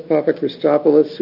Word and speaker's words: Papachristopoulos: Open a public hearing on Andreas Papachristopoulos, who is being appointed to the Oregon Board of Maritime Pilots. – Very well pronounced, Mr. Vice Chair. – Not a problem. – I've been practicing it.